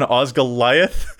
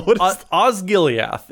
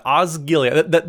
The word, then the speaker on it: Osgiliath: Assault on Osgoliath is the name of the am I doing it right? – No. – No. How's it pronounced? I don't know Lord of the Rings that well. – Osgiliath is what we're looking at. Osgiliath.